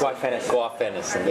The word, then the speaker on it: guaifenesin: And then if you have a wet cough, that could be any product that contains guaifenesin. I always mispronounce. – Guaifenesin.